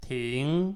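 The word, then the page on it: ti2ng: tone 2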